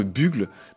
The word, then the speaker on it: Bugle: Et Bugle, c'est, alors, qui s'écrit bien B-U-G-L-E, qui se prononce Bugle en anglais, à la forme d'un corps de chasse, d'un clairon.